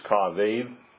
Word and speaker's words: kavod: But the word in Hebrew, if you couldn't hear it up here, is kavod.